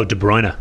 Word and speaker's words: De Bruyne: Oh, De Bruyne.